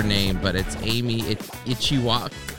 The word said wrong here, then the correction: Ichikawa: Damn it. – Oh, my God. – Okay, wait, Amy, I'm going to get it. I promise. Amy Ichikawa. Boom.